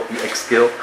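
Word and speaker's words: Uexküll: píše se to hrozně to jméno, ale vyslovuje se to Uexkill,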